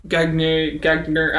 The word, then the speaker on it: Gagner: Nuge, Ratty, and Gagner, I don't know how to say Gagne.